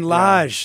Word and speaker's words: Lage: And another listener wanted to shout out Julian, I think it's Lage? Julian Lage.